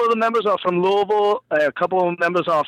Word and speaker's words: Louisville: Did I say it wrong? Is it Louisville? – Yeah, it's Louisville. You're good.